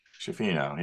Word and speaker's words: Schifino: Schifino, yeah. – Thought he'd take it to the hoop instead of trying to find our best player on a driving dish. – Yeah, well, I think Hood Schifino might think he's the best player.